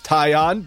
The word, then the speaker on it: Taillon: Jameson Taillon, after 15 minutes of Googling to figure out how to pronounce his last name, I am 99.9% sure it's Taillon, because I found a Sportsnet Pittsburgh broadcast that introduced the man.